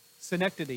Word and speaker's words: synecdoche: Syndakake, maybe that's how it's pronounced. Syndakake. Synecdoche, yeah. Now, if you use the Greek pronunciations, it might sound a little different, but you're right.